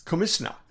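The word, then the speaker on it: Komitina: K-O-M-I-T. But that's, remember, in Gilbertese, T-I is pronounced S. It's Comisna, commissioner.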